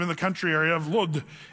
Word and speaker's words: Lod: Now, I just want to say, this is kind of fun here, because this place, Lydda, it's also in modern day, it's called Lod, L-O-D. And that's how they say it in Israel, Lod.